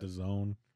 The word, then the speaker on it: DAZN: What's the DAZN or DAZN? How do you say that? – DAZN. – That and it's distributed by ESPN and that DAZN, really? – Yeah, like Calzone. – That's how I've heard it pronounced.